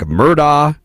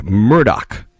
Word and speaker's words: Murdaugh: Well, the official pronunciation, according to the attorney, is Alec Murdoch. That's how he's been addressed in the courtroom. Apparently, this is how the family has pronounced the name for quite some time.